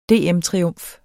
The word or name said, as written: DM-triumf